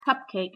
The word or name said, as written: cupcake